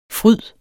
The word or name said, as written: fryd